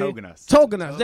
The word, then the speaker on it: Tauginas: Tauginas.